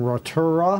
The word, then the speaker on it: Rotorua: We got a new world record from Adams Jeffries from Rotorua.